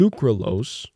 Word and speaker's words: sucralose: A study suggests sucralose. Is that how you pronounce it? Sucralose? – Yep.